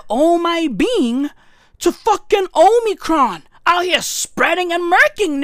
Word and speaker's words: Omicron: The word is Omicron. That's how you say it. That's how you pronounce it.